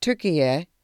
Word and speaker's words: Türkiye: Türkiye is how the country's name is pronounced in Turkish.